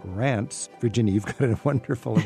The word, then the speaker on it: Reims: How would you say it in French? – Reims. – Reims. – And then you have to pronounce the S at the end, which usually we don't. We don't say Paris, we say Paris. – Right. – But we say Reims, so it's the exception.